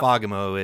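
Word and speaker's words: Fagemo: I know we weren't sure last week, but yep. Fagamo is the correct way to say it.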